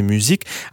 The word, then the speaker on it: musique: En écoutant la version originale de 79, on peut entendre que le Pop Music est prononcé musique à la française et non pas music, tout simplement parce que les chœurs sont assurés par Brigitte Vinchon, artiste parisienne et fiancée de Robin Scott alias M.